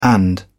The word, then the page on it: and: British English